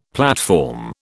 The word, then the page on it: platform: British English